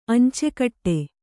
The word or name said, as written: ಅಂಚುಗಟ್ಟು